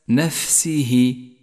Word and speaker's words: نفسه: لاحظ بعد ذلك صفة الهمس لحرف الفاء في كلمة نفسه، فالواجب هنا أن يجري النفس مع حرف الفاء لتقويته، وهذا ما سميناه همسا، تقرأ هكذا نفسه.